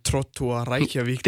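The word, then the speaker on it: Þróttur Reykjavík: Så hvis du skulle til at spørge, hvordan det var anderledes, og ligesom være i, var det Trottur Reykjavik? – Det er flot udtalt, ja, det der.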